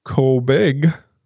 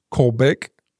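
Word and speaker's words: Kolbigk: And you're not going to know because I'm not going to know how to pronounce it, but Kolbig, it's K-O-L-B-I-G-K, Kolbig, maybe? – Kolbig.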